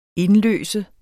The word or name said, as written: indløse